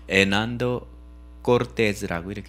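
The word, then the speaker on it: Hernando Cortés: 왜 내가 왜 Hernando Cortes라고 하는지 알아요? H 발음은 얘네들은 안 해요. 그 에스파냐 사람들은 그래서 이걸 Hernando Cortes라고 이렇게 읽어야 되는 거죠.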